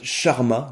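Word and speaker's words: Charmat: qu'on appelle aussi méthode Sharma, donc ça s'écrit C-H-A-R-M-A-T, c'est le nom de, la personne hein, qui l'a mise au point.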